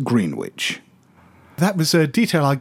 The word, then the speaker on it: Greenwich: Greenwich Village in New York City and Greenwich, Connecticut are pronounced in the same manner as Dunwich in the UK, Greenwich.